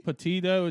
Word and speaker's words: Petito: Gabby Petito, something like that is her name. I'm sorry if I'm pronouncing her last name wrong. – Petito, maybe? – Petito. It's P-E-T-I-T-O.